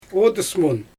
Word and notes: odys mun: Xyzyl

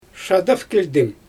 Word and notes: ʃadafkilʲdɪm: Xyzyl

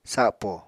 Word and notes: sapo: apatani

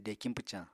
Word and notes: dekiñ pwcañ: apatani

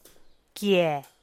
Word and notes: kiee: Teotitlán del Valle Zapotec

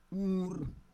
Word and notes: ur: Roon

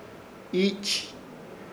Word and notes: Iich: Ishir; Chamacoco

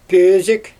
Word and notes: pöözIk: Xyzyl

